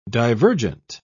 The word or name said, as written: divergent